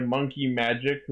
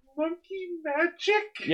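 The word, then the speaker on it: Monkey Majik: So the theme of the movie is going to be called Frontier, which will be performed by Monkey Magic, who also did SOS for Metsubo Jinrai. – Monkey Magic? – Yeah, the best part is it's spelled M-A-J-I-K.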